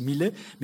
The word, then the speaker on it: Millet: Et il se trouve dans les dessins de Tissot, en Angleterre, un dessin qu'il a dédié au peintre Millet, non pas notre Jean-François Millet à nous, d'ailleurs qu'il faut prononcer Millet, mais Millet, M-I-L-A-I-S.